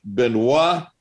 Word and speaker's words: Benoit: So our other audible, and because he includes the accent over his the I in his first name, I'm going to give this the French pronunciation. This comes from Coach Benoit Drouin.